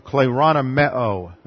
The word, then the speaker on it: kleronomeo: The word inherit here is the aorist active infinitive of kleronomeo. That's spelled K-L-E-R-O-N-O-M-E-O.